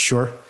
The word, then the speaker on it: shi: And so there's this concept of she, it's spelled S-H-I, she, but I think it's actually pronounced sure or sure, which is this positional advantage that's obtained over time.